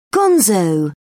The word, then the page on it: gonzo: British English